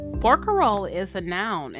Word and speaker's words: barcarolle: English speakers, largely introduced to this musical form through opera, flattened the many vowels of the Venetian word barcarola into the easier to pronounce barcarolle.